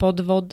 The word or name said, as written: podvod